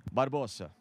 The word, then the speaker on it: Barbosa: Como é que é a pronúncia? – Barbosa. – Barbosa. – Né? Barbosa. – É Barbosa, meu. É Barbosa, brasileirado, hein? – Claro.